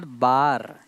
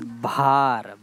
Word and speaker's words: भार: So how do you से it's not बार, इट्स भार.